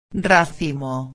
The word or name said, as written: racimo